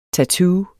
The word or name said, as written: tattoo